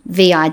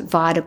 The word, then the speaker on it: Vida: But I've always wondered about what I would have pronounced Vida Goldstein, Vida Goldstein, who was one of the early women independent candidates for parliament in the 1900s. But I found out, and it took a long time, that she actually pronounced it Vida Goldstein, so the exact opposite of what I would do.